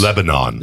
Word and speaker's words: Lebanon: I think they actually pronounce all of the syllables when they're not in Tennessee. – Oh, okay. That's news. – Lebanon.